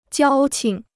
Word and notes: 交情: jiāo qing

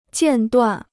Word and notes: 间断: jiàn duàn

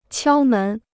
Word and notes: 敲门: qiāo mén

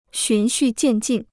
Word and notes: 循序渐进: xún xù jiàn jìn

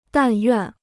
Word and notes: 但愿: dàn yuàn

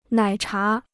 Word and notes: 奶茶: nǎi chá

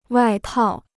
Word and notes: 外套: wài tào

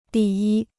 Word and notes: 第一: dì yī

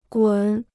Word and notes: 滚: gǔn

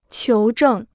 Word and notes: 求证: qiú zhèng

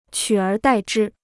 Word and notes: 取而代之: qǔ ér dài zhī

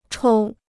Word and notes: 抽: chōu

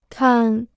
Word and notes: 摊: tān

